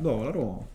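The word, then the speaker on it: Dalarö: Cioè, ero andato in un posto a fare kayak a eh, Sud Stoccolma e si chiamava, se lo leggi, si legge, si scrive Dalaro. Okay. Invece dove sei andato? Dalaro.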